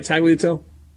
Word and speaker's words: tagliatelle: Tagliatelle? – Yeah.